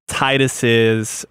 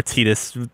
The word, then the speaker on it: Tidus: It's the soundtrack, and I think it's also all of the dialogue with Tidus's or Tidus, depending on how you pronounce it.